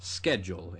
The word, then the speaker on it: schedule: And typically speaking, a lot of Greek loan words that begin with S-C-H, like the word school, are pronounced sk. Schedule in this case, whereas it's words like schmaltz that came from schmaltz.